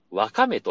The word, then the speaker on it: わかめ: わかめというのが正しいという。でね、えー、一般的にはワカメっていう、かの方にね、あの、アクセントつけることが多いんですけど、